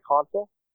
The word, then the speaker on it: Consul: It's Caught by Console. So my last name is spelled C-O-N-S-U-L.